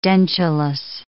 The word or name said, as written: dentulous